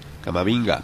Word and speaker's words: Camavinga: Eduardo Camavinga, no sé cómo se pronuncia. – Camavinga.